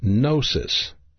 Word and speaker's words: gnosis: One of the words that you will find is gnosis, and that's pronounced G-N-O-S-I-S. Gnosis.